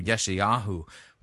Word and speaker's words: Yeshayahu: God commands the prophet Isaiah, by the way, it's pronounced in modern Hebrew, Yeshayahu, but anyway,